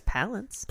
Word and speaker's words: Palance: I think it's Palance.